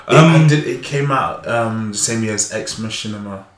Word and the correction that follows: Ex Machina: I know Ex Machina. – Ex Machina.